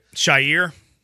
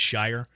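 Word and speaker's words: Al-Shaair: Shaiir, he called him. Aziz Al-Shaiir, he called that to Aziz, which it's, as we all know, it's Shire.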